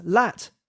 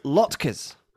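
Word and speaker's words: latkes: The proper pronunciation is as if you were saying the word lot followed by ker. Rory had pronounced it as lat.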